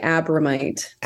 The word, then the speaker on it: Abromeit: It rhymes with dynamite, Abromeit.